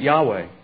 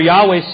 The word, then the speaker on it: Yahweh: And there is, no one really knows if we're pronouncing it right. It's Y-H-W-H. And many pronounce it Yahweh.